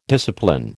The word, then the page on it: discipline: American English